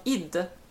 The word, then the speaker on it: id: Jag lärde mig någonting nytt också under den här mässan, nämligen att det uttalas inte ID Software utan ID.